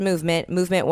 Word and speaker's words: MVMT: So when MVMT, pronounced movement watches,